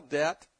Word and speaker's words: debt: Can you spell debt? I don't think Congress can spell debt. It's D-E-B-T, if you haven't noticed. There is a silent B in there.